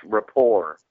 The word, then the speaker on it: rapport: Hey, Justin, you're saying it wrong, too. It's rapport. – A rapport, yes. Okay. Rapport. But we're above that, but I 100% agree with you on Ultraman.